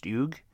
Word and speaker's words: Hugues: This involves a young man, 36-year-old man named, now, the name is H-U-G-U-E-S. He's French. It's pronounced Hugues de la Plaza.